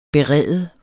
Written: berede

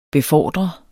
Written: BEFORDREDES

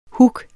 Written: hug

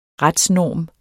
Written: retsnorm

